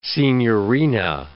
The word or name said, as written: signorina